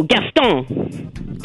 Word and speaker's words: Gaston: Oh, Gaston.